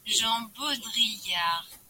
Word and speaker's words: Baudrillard: Como que pronuncia? Parará. Francês. Jean Baudrillard.